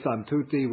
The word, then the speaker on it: santuti: And normally santuti would end with the short I, but here it's extended to a long I for the purpose of the meter.